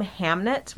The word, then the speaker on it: Hamnet: And don't think Jake is pronouncing it wrong. The name Hamnet, which is the title of the book, I guess was interchangeable historically with Hamlet.